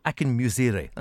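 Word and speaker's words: Akinmusire: Ambrose Akinmurise, I can never get the name right. Akinmurise, that's better.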